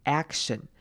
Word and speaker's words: action: 来看今天第一个重点呢，是 action movie 的 action 这个字，这个字要特别注意的是开头的 a 要念成短母音 a 的声音，记得要把 a 的声音发出来，把嘴巴张大一点，action action。